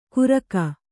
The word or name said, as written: ಕುರಕ